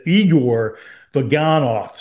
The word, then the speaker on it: Igor Vaganov: I've gotten some information from people commenting that he really pronounces it Igor Vaganov.